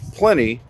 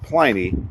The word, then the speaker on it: Pliny: Starts off, it's Pliny, not Pliny. Pliny rhymes with many.